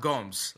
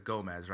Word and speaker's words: Gomes: Yeah, it's pronounced Gomes, right? Like, that's how it's pronounced. But, you know, we try – like, when I see it, I automatically says Gomez, right? Because that's just, like, the Spanish style.